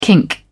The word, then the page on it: kink: British English